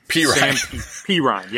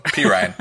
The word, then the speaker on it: Perine: So this is pronounced pairing. – Perine. Yep. – those of you listening to me know I can't pronounce anybody's name. So Perine.